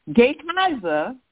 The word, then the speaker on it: Gehazi: But Elijah's servant, and I'm pronounce the man's name wrong. Let me try this again. Gehazi was Elijah's servant, and instead of allowing Naaman to go away, he's pursued after Naaman and said, oh, Elisha's changed his mind.